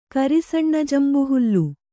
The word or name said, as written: ಕರಿಸಣ್ಣಜಂಬುಹುಲ್ಲು